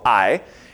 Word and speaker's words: Ai: Ai, you might have heard that called Ai, but it's just one syllable, Ai.